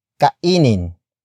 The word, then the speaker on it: kainin: Word for word it's kainin, mo, ang, isda, kainin, mo, ang, isda, kainin, mo, ang, isda. The first word is kainin.